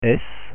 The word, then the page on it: s: French